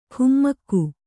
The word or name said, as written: ಖುಮ್ಮಕ್ಕು